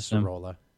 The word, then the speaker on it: Sorolla: I think it's Sorolla. I trust my boy Dan Hord, and Dan Hord was pronouncing it Sorolla.